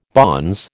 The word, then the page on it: bonze: American English